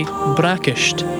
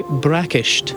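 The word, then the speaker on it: bracaist: To say breakfast in Scottish Gaelic, you'd say brakisht, brakisht.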